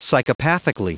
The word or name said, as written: psychopathically